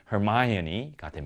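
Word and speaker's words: Hermione: 영어 발음은 허마이오니가 됩니다.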